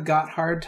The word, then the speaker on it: Gotthard: It's spelled G-O-T-T-H-A-R-D. I'm going to say it's Gotthard. – I say Gotthard or Gotthard. – We'll call it the Gotthard Tunnel.